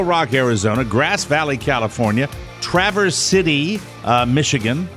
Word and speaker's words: Traverse City: There's a pronouncer there. Traversity. – Oh, Traversity. – Because they put the S. That's how they say it there, he said. He was very specific.